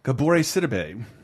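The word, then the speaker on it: Gabourey Sidibe: Gabore Sidibe.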